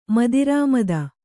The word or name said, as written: ಮದಿರಾಮದ